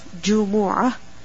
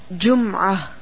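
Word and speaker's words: جمعة: يَوْمِ الْجُمْعَةِ, الْجُمْعَةِ, the word Al-Jumu'ah can be read in different ways. One pronunciation is, as you see in your Mus'haf, Jumu'ah, with the Dhamma on the Jeem, as well as on the Meem.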